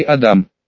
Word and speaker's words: Адам: Я вышел на просторы интернета и начал бегло читать все, что касалось компании «Адам». Ударение, как оказалось, нужно делать на вторую букву, а не как сказала девушка по телефону.